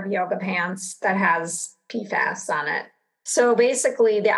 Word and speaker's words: PFAS: PFAS, is that how you say it? – But yeah, like, well, you could say if, fluorinated chemicals or PFAS. PFAS is just what everyone will understand.